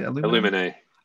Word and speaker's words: Illuminae: Illuminae.